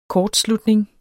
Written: kortslutning

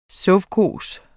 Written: sovkhos

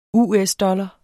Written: US-dollar

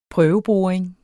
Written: prøveboring

